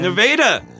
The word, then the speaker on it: Nevada: I went to high school in a town called Nevada, Missouri. – And Nevada! – Yes. And now I finally came here and learned how to say it right.